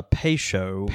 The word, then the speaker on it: Peychaud: But anyway, he says it's a pay show. Like you're paying for something and you're showing something.